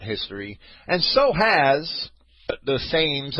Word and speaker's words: Thames: I was corrected tonight. It's the Thames River. I see a T-H and an A, and the English see a T-E. I guess it's their river. I'll pronounce it the way they do.